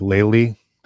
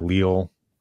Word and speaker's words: Lely: It's Lely. That's how you pronounce that? – Yes, I think so. – Okay. I was always like fucking it up too. Like Leal, I was calling it or something. Yeah. Lely. Okay.